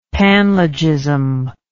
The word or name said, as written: panlogism